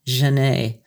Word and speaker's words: Janet: though in fact that's the way Parisians pronounced her name, Janet, Genet.